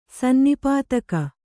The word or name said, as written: ಸನ್ನಿಪಾತಕ